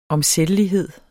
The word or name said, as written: omsættelighed